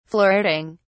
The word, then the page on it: flirting: British English